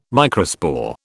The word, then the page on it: microspore: British English